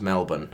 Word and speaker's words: Melbourne: I flew into Sydney, mucked around there for a week, and then I drove down to Melbourne and mucked around there for a week.